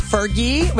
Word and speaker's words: Fergie: And I was like, it's Fergie with a hard G.